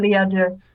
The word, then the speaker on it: à deux: Oh, you you butchered that. – Uh, it's fine. I don't. You know, I'm. Please, here. Actually, why don't you go ahead? Give me the give me the pronunciation before I continue. – à deux. – Yeah, that's way better.